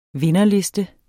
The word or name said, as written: vinderliste